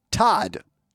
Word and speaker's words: Todd: The word is Todd. T-O-D-D. I'm not making that up. The word is Todd. My first name, T-O-D-D.